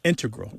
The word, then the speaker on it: integral: But the mathematicians and math using scientists are more likely to say integral with the stress on the first syllable.